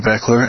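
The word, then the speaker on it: Baechler: Again, my name is Tony Beckler, and thank you for listening.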